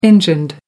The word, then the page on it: engined: American English